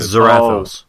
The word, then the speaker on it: Zarathos: Zarathos.